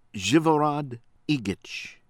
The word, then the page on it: AVRAMOV, SMILJA: SMEEL- yah ahv- RAH- mawv